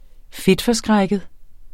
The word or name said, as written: fedtforskrækket